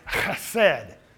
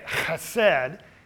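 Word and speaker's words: chesed (חסד): And so the best way they understand that they pronounce it, it's a guttural, like the German hard k, like ich. It's chesed. Now, that's hard for our English tongue to say, chesed.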